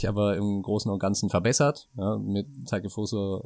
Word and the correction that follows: Takefusa Kubo: äh, Takefusa Kubo wie halt ausgesprochen wird, äh, kommt tatsächlich nochmal ein aufregender Spieler, der ist ja auch nach wie vor erst 19.